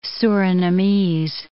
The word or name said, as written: Surinamese